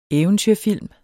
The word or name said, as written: eventyrfilm